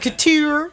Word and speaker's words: Couture: I sat at the Cooter in Paris this week. – The Cooter. – So amazing. The Cooter. Couture.